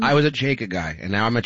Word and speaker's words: Chajka: That is the correct pronunciation. Just think of chai lattes. Chajka. No? Yes, Chajka.